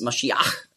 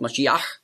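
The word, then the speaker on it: Mashiach (משיח): Und dann Iach mit dem Het am Schluss, Mashiach, hat man früher ausgesprochen, Mashiach.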